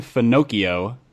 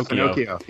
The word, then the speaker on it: Finocchio: There's a guy from Hillsong named Pastor Nathan Finocchio. Rhymes with Pinocchio. – Yeah, Finocchio.